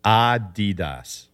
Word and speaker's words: Adidas: It's Adidas. – What do you mean? I'm pronouncing it wrong? – Yeah, because look, this is from the founder's name, Adolf Dassler. His nickname for Adolf was Adi.